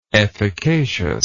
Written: efficaciousness

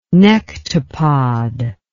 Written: nectopod

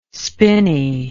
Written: spinney

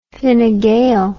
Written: Fine Gael

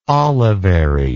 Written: olivary

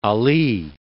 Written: Ali

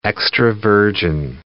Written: extra-virgin

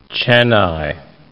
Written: Chennai

